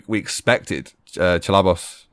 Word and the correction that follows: Ceballos: Did I say his name? Chalabos? – No. – Ceballos. – Forgive me for butchering his name.